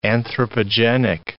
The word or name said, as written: anthropogenic